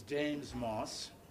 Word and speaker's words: James Moss: My name is James Moss. It's spelled J-A-M-E-S, period. No, I'm sorry. J-A-M-E-S-M, period, M-O-S-S.